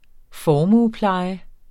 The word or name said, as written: formuepleje